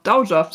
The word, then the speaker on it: Dowager: Nee, das habe ich auch falsch ausgesprochen. – Dowager. – Dowager. Dowager, so wird es ausgesprochen.